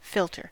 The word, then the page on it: philter: American English